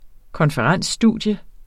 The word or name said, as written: konferensstudium